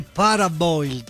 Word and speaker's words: parboiled: Il riso deve essere parboiled. Scritto paraboiled, scrivo bene, mi raccomando. – Paraboiled, si legge però? Parboiled. – Giusto, che è inglese.